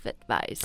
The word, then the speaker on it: advice: 对，所以大家一定要注意啊，它词性不同，拼法和发音都会发生一些变化。刚刚我们看的 A-D-V-I-C-E, advice, 是 noun, 是名词。 – That's right. So you can see ice right on the end. This is the same as the ice you would find...in your freezer or in your Coca-Cola.